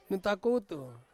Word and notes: nutakutu: Ansus

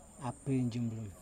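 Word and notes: apin: Kundiman

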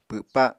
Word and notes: pwpa: Apatani

